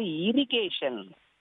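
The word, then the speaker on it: irrigation: TION என்றாலே டியான் கிடையாது, சன் என்று சொல்லுவேன். அதே போல் இரிகேஷன் நீர்ப்பாசனம். இரிகேஷன் is very important to farmers. நீர்ப்பாசனம் விவசாயிகளுக்கு மிகவும் முக்கியமானது. அடுத்தபடியாக ட்ரிப் இரிகேஷன், சொட்டு நீர் இருக்கு பாத்தீங்களா, அது எவ்வளவு நமக்கு வரப்பிரசாதமா இருக்கு.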